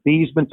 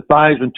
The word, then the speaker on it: Theismann: We think just by simply changing the pronunciation of your last name from Theismann to Theismann to rhyme with Heisman, we believe that can help you get the trophy.